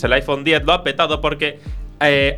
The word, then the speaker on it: iPhone X: su iPhone X o iPhone 10, que tendría que ser en principio esa eh, pronunciación correcta.